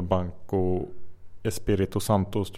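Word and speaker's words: Banco Espírito Santo: Med den här portugisiska banken, den största banken i Portugal, Banco Espirito Santos tror jag den heter. Perfekt portugisiskt uttal. Ja, hyggligt.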